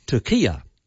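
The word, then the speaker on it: Türkiye: And this new spelling is pronounced Turkiya.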